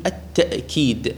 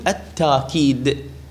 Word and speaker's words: التوكيد: القراءة الأولى تقول التوكيد بالواو التوكيد، والقراءة الثانية تقول بالهمز يعني بالهمزة تقول التأكيد التأكيد، يعني بدل الواو تلفظ همزة. والقراءة الثالثة تقلب الهمزة إلى ألف فتقول التاكيد. الأرجح فيها هو الأول التوكيد كما نص عليه يعني كثير من النحويين، وأيضا نص عليه بعض اللغويين.